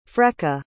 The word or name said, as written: freca